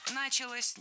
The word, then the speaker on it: началось: И вот именно в тот момент в Голландии, если я правильно понимаю, началось развитие вот именно... – Началось неверно. Вот здесь уже ударным становится окончание. Началось. Дело в том, что возвратная часть «се» во многих словах как бы притягивает ударение к себе.